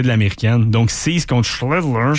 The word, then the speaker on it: Schlittler: Un duel impitoyable, je dirais, avec Cam Schlittler. – Schlittler. J'aime ça de la manière que tu l'as très bien prononcé.